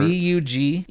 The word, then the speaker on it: Bugenhagen: Megiddo, an old city in Jezreel, and see a man named Bunnenhagen. I was bad at that one. Sorry. – Can you spell it? I don't remember. – Bugenhagen. – Oh, yeah. Bugenhagen. – Bugenhagen. Yeah, that's what I said.